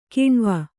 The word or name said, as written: ಕಣುವೆ